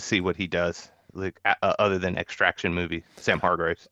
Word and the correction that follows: Hargrave: I mean, I mispronounced it. It's Hargrave.